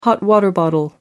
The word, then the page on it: hot water bottle: American English